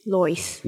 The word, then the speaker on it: Lois: It's L-O-I-S, like Lois. – Lois. – Yeah, I would say the safe pronunciation is Lois.